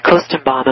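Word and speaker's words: Koestenbaum: Peter Kostenbaum. Am I pronouncing his name correctly? – Yeah.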